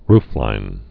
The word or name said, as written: rooflines